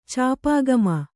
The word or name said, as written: ಚಾಪಾಗಮ